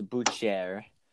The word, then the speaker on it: Boucher: No, it's Boucher.